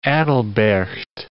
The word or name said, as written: Aedilberct